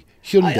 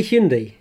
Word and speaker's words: Hyundai: You know the way that Hyundai, how do we say it now? Like Monday. Hyundai. – I still say Hyundai.